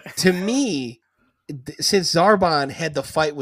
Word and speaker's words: Zarbon: but is it Zarbon? – Zarbon, yeah.